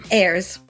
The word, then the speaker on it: Ayers: Ayers. – Ayers. – Despite the Y.